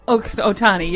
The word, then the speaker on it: Shohei Ohtani: Something else going on with baseball, Shohei Ohtani. Is that how you pronounce it? He hit two home runs. – Ohtani, I think, is one of the... – Ohtani, yes.